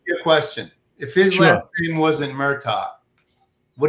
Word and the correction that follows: Murdaugh: It's actually Murdaugh, like Murdaugh. It's almost like Murdaugh, like Murdaugh. It's pronounced.